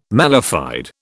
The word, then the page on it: mala fide: British English